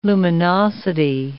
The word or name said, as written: luminosity